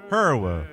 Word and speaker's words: Hirw: Super fan Linda Herr. Now, Herr is spelled H-I-R-W. Mm-hmm. So... I would like to pronounce it correctly as her ancestors did before they came over on the Herflower. Superfan Linda Herwa.